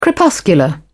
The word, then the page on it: crepuscular: British English